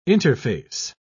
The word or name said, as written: interface